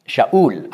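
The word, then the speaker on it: Shaul (שאול): Saul, das ist Hebräisch. Shaul ausgesprochen.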